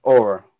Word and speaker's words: Aura: Allure, your name. I'll make sure I'm pronouncing it right, correct? – Aura. – Aura. Like someone's Aura.